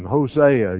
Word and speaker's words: Hosea: That's how I say his name, Hosea, if you say it that way.